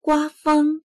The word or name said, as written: gua1feng1